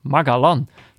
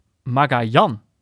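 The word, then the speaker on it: Magallán: Hij sprak de naam van de nieuwe Argentijnse Ajax-verdediger namelijk op zijn Hollands uit. Met een Engelse G, Magalan. Terwijl het in het Spaans natuurlijk uitgesproken wordt, wordt als... Nee, dit snap ik. In het Spaans natuurlijk uitgesproken wordt als Magajan. Met de klemtoon op de laatste lettergreep.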